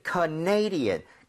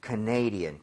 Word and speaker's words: Canadian: 왜냐면 캐나다는 Canada 이렇게 가는데 네. – 이게 캐나다 사람 이러면은 Canadian. 강세가 뒤로 하나 밀려요. – 아. – 그래서 살짝 헷갈릴 수 있습니다. – Yes. – So, Canadian, Canadian.